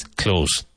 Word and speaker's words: clothes: Now, spelt C-L-O-T-H-E-S. Some native speakers pronounce the T-H, but the most common thing is close. So it should sound exactly like close the door, close the window.